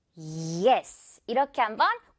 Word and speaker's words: yes: 이 영어에서는 예가 아니라 예. 이렇게 우리랑 달라요. 선풍기 바람 소리가 무슨 말인지 알겠죠? Yes. 이렇게 한번 과장해서 연습을 하다 보면 전반적인 발음이 좋아질 거예요.